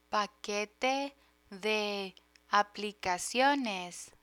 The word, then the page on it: paquete de aplicaciones: Spanish